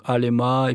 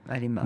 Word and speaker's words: Alema: Donc, Alema, et puis trois syllabes, en fait, c'est facile à retenir. – Oui, Alema, voilà.